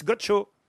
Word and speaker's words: Godchaux: Ah non, mais là, ça ne s'écrit pas Gaucho. C'est Gaucho comme Gaucho. G-O-D-C-H-A-U-X, Gaucho.